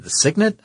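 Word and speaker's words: Cygnet: They make that vehicle that's called the Signet, I believe? – But it's not spelled like the word Signet. It's C-Y-G-N-E-T. – Yeah, I think.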